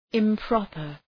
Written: improper

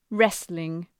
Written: wrestling

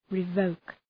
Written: revoke

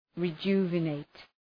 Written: rejuvenate

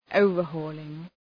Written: overhauling

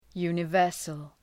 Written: universal

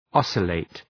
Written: oscillate

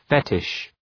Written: fetish

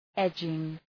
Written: edging

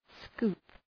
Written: scoop

